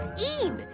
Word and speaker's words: Een: The Scottish word for Eve is pronounced Een.